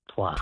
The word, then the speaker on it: toit: It's pronounced toit.